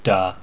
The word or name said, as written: da